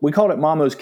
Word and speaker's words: Maamo: We called it Maamos Kitchen because Maamo is the Finnish word for mother, and phonetically it sounds like mom.